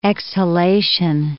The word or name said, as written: exhalation